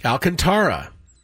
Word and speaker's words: Alcantara: Sandy Alcantara, and we did look this up. That is correct. – Alcantara. – People mispronounce it like me all the time.